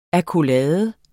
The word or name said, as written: AKKOLADES